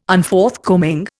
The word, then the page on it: Unforthcoming: Indian